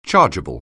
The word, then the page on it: chargeable: anglais